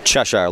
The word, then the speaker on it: Cheshire: Cheshire, like the cat.